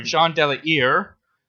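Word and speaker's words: Jean de La Hire: Jean Delahire. That's my best approximation of the French pronunciation I found.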